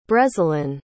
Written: brezilin

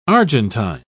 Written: argentine

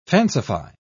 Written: fancify

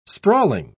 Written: sprawling

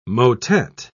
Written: motet

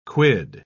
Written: quid

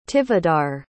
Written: tivadar

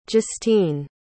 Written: justeen

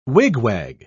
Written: wigwag

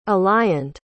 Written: alliant

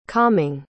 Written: calming

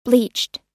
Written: bleached

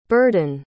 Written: burhdon

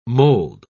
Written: mould

